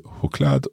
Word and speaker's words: choklad: And she said, choklad och jordgubb. So chocolate and strawberry, I think? – Yes, and very good pronunciation of choklad.